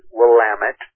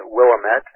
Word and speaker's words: Willamette: Here in the Portland area, we are told on a regular basis, downtown Portland, the Willamette River, and it is pronounced Willamette. Some people mispronounce it Willamette, and that's how we know that they're a tourist.